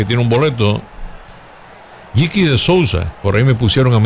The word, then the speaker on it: Sousa: Un nuevo pitcher es Sousa. Por lo menos así lo pronunciamos en español.